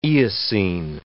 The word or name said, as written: Eocene